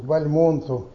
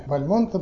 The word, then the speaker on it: Бальмонт: Но они большие патриоты, они очень упорно произносят Бальмонт. Потому что считают, что фамилия произошла от предков неких баламутов. Вот. Но для Бальмонта это было его артистическое имя. И вот Цветаева рифмовала «Бальмонт, горизонт».